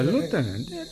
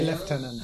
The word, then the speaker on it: lieutenant: Ah, lieutenant. – Americans say lieutenant. – Huh? Is it? General. – General. We call lieutenant.